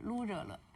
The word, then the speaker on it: 卢热勒: 对，它是卢姓的赫哲语的发音。就是这一个卢字，然后是卢热勒。